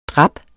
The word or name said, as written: drap